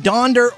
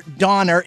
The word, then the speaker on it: Donder: It's Dasher, Dancer, and Donder or Donner. It's both, but Donder is the original Dutch spelling meaning thunder, while Donner became popular through the Gene Autry song and is the common spelling now, though some sources like California stick with Donder. – You know what? Donder rhymes with yonder, which is over where you can find someone who gives a flip.